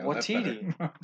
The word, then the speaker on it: Waititi: Waititi.